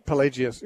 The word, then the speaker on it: Pelagius: Pelagius, yeah.